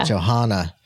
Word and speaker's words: Johanna: Am I saying that right? – Johanna.